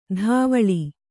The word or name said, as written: ಧಾವಳಿ